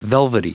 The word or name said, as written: velvety